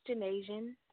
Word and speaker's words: Janasian: My name is Janasian.